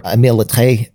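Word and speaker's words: Littré: And social generation appeared more as a name for the concept when the French lexicographer Émile Lettré defined a generation as all people coexisting in society at any given time. – Can I just say points for you on that pronunciation? – Thank you.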